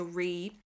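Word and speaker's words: rí: So re, which is or I, fada, with, you know, fada is the accent on the I, meaning king, or rean or ban rean for queen.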